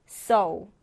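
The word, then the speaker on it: sew: su가 아니고 so. so 이렇게 발음 기호 잘 보고 읽어주시면 되는데, 이 so라는 단어는 뭔가 찢어진 것을 바느질하다, 꿰매다라는 뜻입니다.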